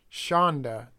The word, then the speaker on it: Schanda: Baumgartner is the romantic partner of Wilfong's uncle, Lawrence Philip Shanda, that's spelled S-C-H-A-N-D-A.